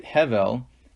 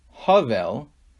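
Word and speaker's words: hevel (הבל): If you pronounce it hevel, it means futility. But if you pronounce it havel, with a ah instead of a e, instead of hevel, it's havel, it doesn't mean futility, but it means breath.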